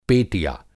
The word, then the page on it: पेटिया: Hindi